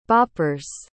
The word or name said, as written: boppers